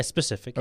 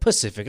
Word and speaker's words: specific: Did I say it correctly? What? Specific? – Yeah, specific. – Okay. – Yeah. When you asked me in the first thing, you're like, Pacific.